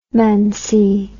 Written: mancy

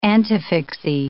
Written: antefixae

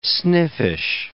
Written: sniffish

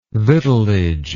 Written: victualage

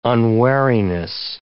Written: unwariness

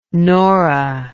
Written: nora

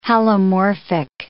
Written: halomorphic

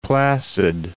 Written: Placid, Lake

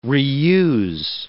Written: reuse

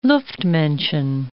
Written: Luftmenschen

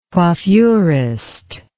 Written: coiffurist

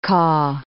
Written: caw

